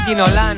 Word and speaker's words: Ginola: Mira, nos decía un oyente, Cantona o Ginola no tenían acento y se acentuaban en la última sílaba. Los acentos correcto. Del francés no funcionan como los del español.